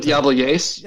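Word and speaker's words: Jebel Jais: You, when do you pronounce it? It looks, it's like, I went with Yebel Yais. Oh, how does like that sound? – That sounds much better than how I was starting.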